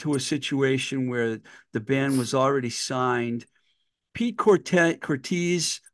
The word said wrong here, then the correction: Cortese: I'll never say his name right, Mike D. – Cortese. I always thought it was Cortez. – Yeah, but I know who he is. – It's like Scorsese.